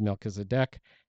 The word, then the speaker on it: Melchizedek: I hear lots of pronunciations of the word Melchizedek. However, I did look it up, and the accent is on the last syllable. And there's a ha in there, Melchizedek.